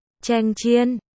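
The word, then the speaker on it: cheng chiên (强箭): Từ cường tiễn đọc là cheng chiên, hài âm hoàn toàn với cưỡng gian,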